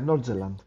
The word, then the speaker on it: Nordjylland: I believe it was the first half against Nordjylland. Is it, am I pronouncing that correctly? – Yeah, Nordjylland. – Yeah, the first half, we looked very suspect. We switched to the 4-4-1-1, looked a little bit better.